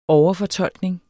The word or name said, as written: overfortolkning